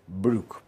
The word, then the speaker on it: Broek: Broek, que se pronuncia Brook, porque el diptongo OE en neerlandés se pronuncia U, como en Boer, que se pronuncia Bur.